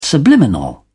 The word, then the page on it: subliminal: British English